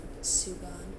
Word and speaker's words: sugan: S-U-G-A-N. Sugon. – Nope, that's a phone. I've only heard one person pronounce it, and they said sugon.